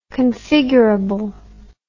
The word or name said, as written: configurable